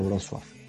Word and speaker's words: Wrocław: Se escribe Wrocław, pero como la L con el palito en polaco es una U, se pronuncia Wrocław.